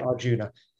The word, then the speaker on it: Arjuna: But you drop the A when you say it in Indian. So it's like Arjun. So you say, and it'll just be a slight inflection.